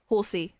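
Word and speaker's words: Horsea: A Horsia. – Horsey. It's pronounced a Horsey. – It's like a seahorse, but backwards.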